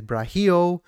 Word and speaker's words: Braulio: And by the way, his name is spent Braulio, but I think the pronunciation is Brahio.